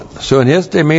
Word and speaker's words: sunistemi: Hotheos, the God, and then the verb sunistemi. That's S-U-N-I-S-T, long E-M-I, sunistemi.